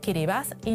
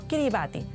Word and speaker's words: Kiribati: Y por cierto, se pronuncia Kiribati y no Kiribati, según el idioma local, el gilbertés.